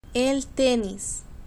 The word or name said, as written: el tenis